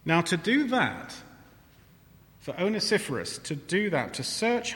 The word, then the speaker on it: Onesiphorus: We have a man called Onesiphorus.